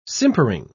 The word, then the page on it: simpering: British English